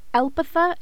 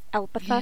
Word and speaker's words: Elpitha: Elpitha is a big one. I don't know why they put the emphasis on the E and not the I. It's Elpitha.